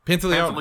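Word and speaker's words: Pantoliano: How do you say his last name? Pantaleone? – No, Pantaleone. – Pantaleone. – Pantaleone, if I remember correctly.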